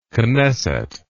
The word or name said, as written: knesset